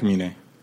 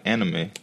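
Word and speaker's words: Aminé: I really like Amine. – Who the fuck is Caroline. – He made that Caroline. – Song. – That's how you say his name. I used to call him like anime.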